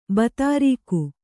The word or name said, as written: ಭೂದುರ್ಗ